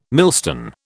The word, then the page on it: millstone: British English